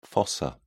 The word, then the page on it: fossa: British English